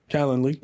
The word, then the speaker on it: Calendly: How have you pronounced that? For scheduling. – Calendly. Yeah.